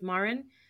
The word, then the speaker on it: Marin: It's Marin.